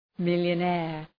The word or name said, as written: millionaire